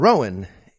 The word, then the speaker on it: Rowan: Quote, the row in Rowan is not pronounced row as in to row a boat. Instead, it is pronounced more like cow, like when used to describe an argument. So imagine the word Cowan and replace the C with an R.